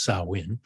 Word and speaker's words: Samhain: Their Samhain, it looks like it should be pronounced Samhain, but it's apparently pronounced Samhain, was celebrated on October 31st, observing the belief that the spirits of the dead returned to earth that night.